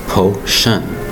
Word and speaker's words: potion: Today's word is potion, P-O-T-I-O-N. Notice two distinct syllables, potion. Look how we're spelling it, P-O-T-I-O-N. The T-I-O-N is pronounced shun. You might say to yourself, why isn't there an S-H in there for the sh sound? In this case, the T-I-O-N makes that sound.